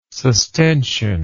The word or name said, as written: sustention